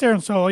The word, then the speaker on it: Aaron Solo: And I probably just mispronounced his last name, but no, it's Aaron Solo. You got it right.